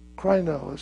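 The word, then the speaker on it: krino (κρίνω): Krino is the way it's pronounced. At least that's the way I'm pronouncing it.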